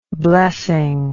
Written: blessing

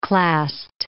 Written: clast

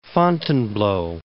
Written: Fontainebleau

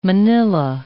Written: Manila

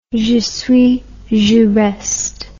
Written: j'y suis j'y reste

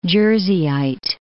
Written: Jerseyite

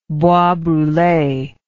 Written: bois brule

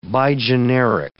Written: bigeneric

